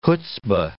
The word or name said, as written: chutzpah